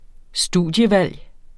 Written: studievalg

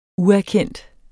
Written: uerkendt

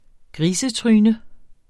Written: grisetryne

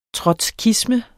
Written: trotskisme

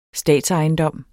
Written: statsejendom

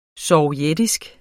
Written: sovjetisk

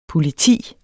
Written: POLITIET

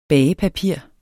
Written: bagepapir

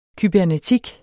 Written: kybernetik

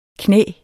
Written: knæ

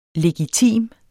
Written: legitim

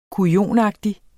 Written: kujonagtig

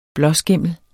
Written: blåskimmel

